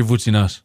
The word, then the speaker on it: Voutsinas: Dimitri Voutsinas.